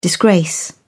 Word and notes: disgrace: British English

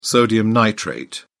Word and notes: sodium nitrate: British English

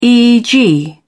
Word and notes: eeg: British English

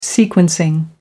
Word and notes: sequencing: American English

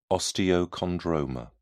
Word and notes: osteochondroma: British English

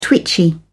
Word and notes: twitchy: British English

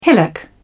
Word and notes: hillock: British English

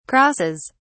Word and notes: crosses: British English

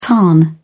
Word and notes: tarn: British English

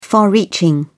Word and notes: far reaching: British English

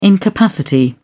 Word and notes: incapacity: British English

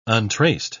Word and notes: untraced: British English

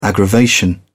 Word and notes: aggravation: British English

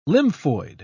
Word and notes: lymphoid: British English

